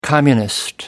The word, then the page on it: communist: British English